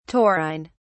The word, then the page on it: taurine: British English